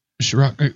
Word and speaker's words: Sriracha: Sriracha.